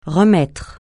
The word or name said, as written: remettre